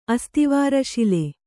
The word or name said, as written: ಅಸ್ತಿವಾರಶಿಲೆ